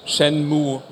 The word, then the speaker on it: Shenmue: Es ist einfach wirklich Shenmue mit einem langgezogenen U-Sound am Ende. – Dann bleiben wir bei Shenmue.